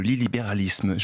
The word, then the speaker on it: illibéralisme: l'illibéralisme. Je l'ai bien prononcé ? – Oui, oui, très bien, très, très bien.